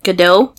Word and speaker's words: Gadot: Gadot. That's how you say her name.